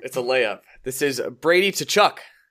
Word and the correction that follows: Tkachuk: Nope. – Tkachuk. – Tkachuk. – Tkachuk. – The T almost doesn't exist.